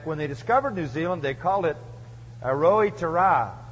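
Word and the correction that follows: Aotearoa: I'll say it right, Aroitiara.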